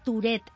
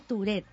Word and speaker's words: Tourette: Se dice Tourette, Eva. Se escribe Tourette, pero se dice Tourette.